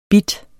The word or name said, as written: bit